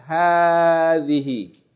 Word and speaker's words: هذه: And هذه is the feminine form of that, right? هذه. It's written without the aleph, but it's pronounced هذه.